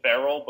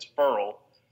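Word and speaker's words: Ferrell: But apparently he told the reporters his name is pronounced Cleland Furl, because they were calling him Cleland Farrell, but it's Furl.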